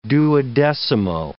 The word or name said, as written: duodecimo